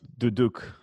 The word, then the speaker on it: duduk: Is it the duduk? – It's duduk. Duduk. Armenian.